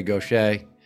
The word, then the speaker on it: Gauthier: My guest today is Mary Gauthier. If you're looking to find her online, it's spelled G-A-U-T-H-I-E-R, but we're going to pronounce it correctly right here on the moment.